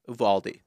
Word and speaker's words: Uvalde: Uvalde, Texas. – Uvalde.